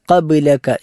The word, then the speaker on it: قبلك: മൂന്ന് അക്ഷരങ്ങളാണ് ഈ ഭാഗത്ത് ഉച്ചാരണത്തിൽ നമ്മൾ ശ്രദ്ധിക്കേണ്ടത്. ഒന്ന്, അല്ലാഹുമ്മ അൻചൽ ഔവലു ഫലൈസ ഖബ്ലക, അവിടെ ഖാഫ് ഖബ്ലക എന്ന ഒറ്റ വാക്കിൽ തന്നെ അറബിയിലുള്ള രണ്ട് കാവും വരുന്നുണ്ട്. മലയാളത്തിൽ ക, അതുപോലെ ഇംഗ്ലീഷിൽ കെ എന്ന അക്ഷരമുള്ളത് ഖബ്ലക എന്ന അവസാനത്തെ കാഫാണ്.